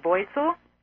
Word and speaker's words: Wurzel: His name is pronounced Wurzel, and it's a German name.